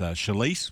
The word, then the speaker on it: Sileas: You'll see the name in the race book, folks, or on Racing Australia as Shalice, which is spelled S-I-L-E-A-S.